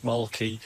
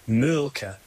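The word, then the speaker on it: Mölkky: We use Molky. Most of the people we play against use Molky, but the Finns have a very unusual pronunciation, which, please forgive me, I'll try it. It's Mölke. So I think that's, something a bit more like that. But to us, it's Molky.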